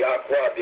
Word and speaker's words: Yahweh: Yahweh, the original pronunciation of which is well attested, as Yahweh judges from Greek transcriptions.